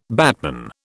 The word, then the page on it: batman: British English